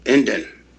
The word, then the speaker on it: NDN: Indian Way is what we go by, that's spelled N-D-N, pronounced Indian.